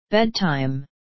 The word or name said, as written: bedtime